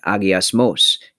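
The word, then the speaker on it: agiasmos (ἁγιασμός): Many of our listeners will know that the two English words, holiness and sanctification, are the same word, agiasmos, in the original Greek language of the New Testament. How did I do in my pronunciation there, Mike? – Very good pronunciation.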